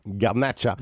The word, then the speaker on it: Garnacha: Garnacha.